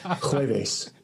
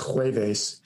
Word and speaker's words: jueves: No, they call it jueves. Jueves. So jueves with a J, J-U-E-V-E-S, jueves, is obviously Jove's day.